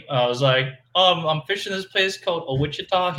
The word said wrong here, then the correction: Ouachita: He's like, that's Ouachita, son.